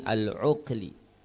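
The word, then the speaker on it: Al-Uqli (al-ʿUkli): Abu Husain Al-Uqli. Ain, alif lam, ain, kaf, lam, ya, Al-Uqli.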